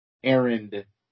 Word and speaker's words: Arnett: How do you pronounce it? – Errand. – Errand. So is the T silent? – I assume.